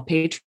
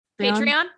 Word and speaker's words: Patreon: Patron? No, Patreon. – Patreon.